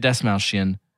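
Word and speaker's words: Dastmalchian: Yes, David Desmalchian.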